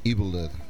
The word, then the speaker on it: Evil Dead: Evil Dead.